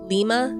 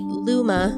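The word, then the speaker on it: Louima: Volpe arrested 30-year-old Abner, I'm not sure how to pronounce the last name, Luima? Lima? – Can I see it? – Yeah, I'll spell it for you. Luima? – I-M-A. – Yeah. Luima. Luima.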